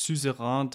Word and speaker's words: Suzerain: In diesem Gamecheck möchten wir uns das Spiel Suzerain angucken oder Suzerain. Es gibt leider nicht viele Möglichkeiten herauszufinden, wie man das richtig ausspricht. Ich habe mir ein paar YouTube-Tutorials und Gameplays angeguckt und da ist Suzerain das am meisten benutzte.